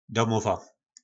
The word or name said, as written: domova